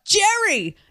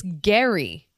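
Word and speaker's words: Gerry: His name is spelled G-E-R-R-Y. I'm having a lot of trouble with names today. And I thought, Jerry the Bachelor, right? Nope. It's Gary. Like Gary, but Gary.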